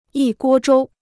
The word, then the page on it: 一锅粥: yī guō zhōu